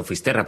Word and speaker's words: Fisterra: Entonces, llegó a Finisterre. ¿Se dice así? – Fisterra.